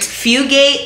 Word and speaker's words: Fugate: Like, Fugate. It's spelled like it sounds. F-U-G-A-T-E.